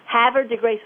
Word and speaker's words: Havre de Grace: It's Havre de Grace, like, as he says, have her win de Grace. And he says that's how everybody pronounces it in Havre de Grace, Maryland. So he says it's the butchered French.